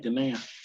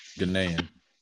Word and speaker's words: Ghanaian: Oh, it's so yeah, you've been saying it right, Ghanaian. – Ghanaian.